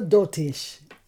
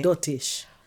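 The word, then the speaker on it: doltish: Doitish. – Doitish, it's a dotish. That's what the daddy's always saying. It's so dotish from Dominica, they say it like dotish.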